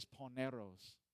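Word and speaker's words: poneros (πονηρός): So the Greek word is poneros, and that's how you pronounce it in the Greek. I know it sounds like I'm trying to speak Spanish, but that's how you pronounce it, poneros.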